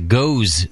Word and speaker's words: Gose: Would Jamil ever consider a show on the GOES style? They spell it G-O-S-E. GOES style of beer.